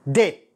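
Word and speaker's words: debt: ديت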